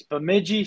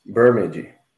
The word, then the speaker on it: Bemidji: You played for Bajimi, I'm butcher this. Bemidji State, correct, for 4 years? – Yeah, that's right. Yeah, yeah. Bemidji.